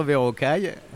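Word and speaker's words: Verocai: Et j'ai eu le bonheur de découvrir donc Arthur Verroquet. Et tu m'as recommandé. Alors ouais, mais je vais te laisser le prononcer. Ah vas-y, donc Arthur. – Arthur Verrocaille. – Verrocaille, voilà.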